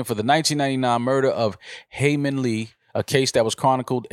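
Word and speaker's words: Hae Min Lee: After 22 years of being convicted of killing Heyman Lee, I believe is her name.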